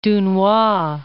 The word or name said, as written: Dunois